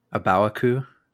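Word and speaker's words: A Baoa Qu: Um, whenever, how do you how do you guys pronounce it? Even if it's in your head, um, the the colony, uh, and I'm just I'm probably going to butcher it um, because I know the show butchers it. Um, Abaqua. Abawaku. Abawaku. Is that how you say it? Yeah, because in.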